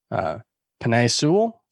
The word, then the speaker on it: Penei Sewell: Cincinnati's offensive line is likely to be better, especially if, you know, I think they should do the obvious and take Penei Sewell.